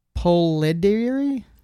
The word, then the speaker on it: Polidori: There was a book by um, John Polidori, Polarity. Hmm. Um, I I can't pronounce this guy's name. P O L I D O R I.